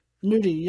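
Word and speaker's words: Nudy: Chief Keef and Young Nutty. – Nudie. Young Nudie.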